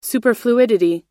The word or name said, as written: superfluidity